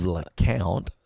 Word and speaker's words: Lecompte: So, or we live in the town of LeCount, spelled L E C O M P T E. – It is spelled like LeCompte and it is pronounced LeCount.